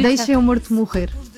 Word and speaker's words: Deixe-me Morto Morrer: It's in Portuguese, it's very hard to pronounce Portuguese, but it's almost Portuguese. – No, you Deixe-me Morto Morrer. We eat the vowels, so it's very hard.